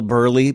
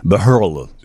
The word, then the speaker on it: Buehrle: B-U-E-H-R-L-E. – It looks like choose from some of these letters to spell Burley. No, that's the letters. B-U-E-H-R-L-E. Burley. – Just a big typo. – The H is silent and the E is silent.